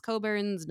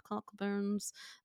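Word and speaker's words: Cockburn's: It's pronounced Coburns, not Cockburns.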